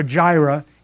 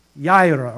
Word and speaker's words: Yireh: And Genesis chapter 22, Genesis 22, verses 13 to 14, He is Jehovah Yireh, Y-I-R-E-H. Now, some pronunciations are Jehovah Jireh. It's actually Jehovah Yireh, or Jehovah Ira. It's you don't pronounce J, but it's Yireh.